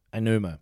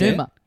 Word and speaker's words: Aonuma: I'm going to butcher the name. Eiji Aonuma? – Aonuma. – Yeah, that's Aonuma. – It. – You got it.